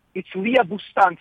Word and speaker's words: Leah Boustan: It's Leah Boustan from Princeton.